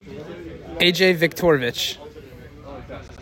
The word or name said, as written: AJ Victoravich